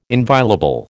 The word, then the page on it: inviolable: American English